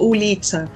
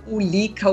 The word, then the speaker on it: ulica: Eu olhava assim, a, por exemplo, rua, Ulitsa, se escreve ulica Aí eu ficava ulica ulica Ulitsa, Ulitsa, Ulitsa.